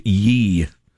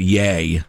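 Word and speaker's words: Ye: which makes it comparable to this year's Yee. Or do you pronounce that Yay? – Yay, I believe. – Yay would make more sense, yeah.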